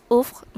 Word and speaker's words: ouvre: So this one is pronunciation is uf macam bila korang mengaji kan yang uh, contohnya qaf bertemu ba dengan lam jadi qab kan. So ini macam uf dia macam v bertemu dengan r bertemu dengan i.